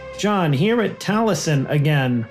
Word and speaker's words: Taliesin: It's pronounced, it looks like Taliesin, like the Book of Taliesin, but it's pronounced Taliesin.